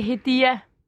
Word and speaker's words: Hediye: Hvordan, hvordan udtaler man dit navn korrekt? – Det er Hedia.